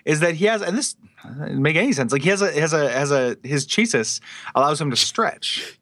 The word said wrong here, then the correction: chassis: I need to talk to you about the word chasis. – Is it chassis? Am I mispronouncing it? – Chassis. I'm sorry.